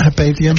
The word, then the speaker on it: Hypatia: Is it Hypatia? – Hypatia.